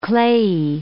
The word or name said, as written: clayey